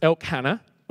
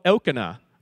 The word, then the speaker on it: Elkanah: Her husband, Elkanah, which I always pronounced Elkhannah when I was a kid because it looks like Elkhannah and Hannah was the wife, so in my head made sense. But I think it's more Elkanah, which is what I found when I was doing some research.